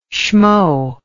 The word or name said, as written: shmo